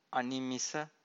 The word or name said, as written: animisa